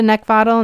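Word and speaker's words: Neckvatal: Brenda Neckvottle, and that's spelled N as in Nancy, E-C-K, like the thing you want to choke, V as in Victor, A-T-A-L.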